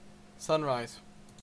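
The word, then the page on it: sunrise: Canadian English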